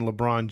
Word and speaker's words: LeBron: But we're going to talk about Akron, Ohio, and that is the hometown of one LeBron James.